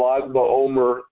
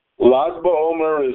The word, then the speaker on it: Lag BaOmer: And a vowel sound is conventionally added for pronunciation purposes. And that is why it is Lag.